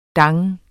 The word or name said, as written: dang